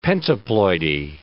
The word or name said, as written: pentaploidy